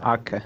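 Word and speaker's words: Aké: Ake się wymawia. Ake.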